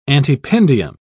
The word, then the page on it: antependium: British English